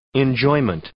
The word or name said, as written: enjoyment